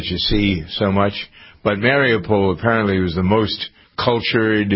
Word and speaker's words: Mariupol: And the only city that really has gone, they've all been destroyed, but isn't it, how do you pronounce it, Mariupol? I think that's how you pronounce it. – Yes. – That's the one.